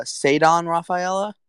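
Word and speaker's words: Ceddanne Rafaela: And we're going to get this off first thing, pronunciation of his name. It's, Sadon Rafaela. So we'll get that, just in case you don't know that.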